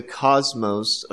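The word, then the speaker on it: cosmos (κόσμος): The word is Cosmos, K-O-S-M-O-S, another fairly familiar word with us because we have an English word that is very much the same, and that is C-O-S-M-O-S, cosmos, pronounced the same way.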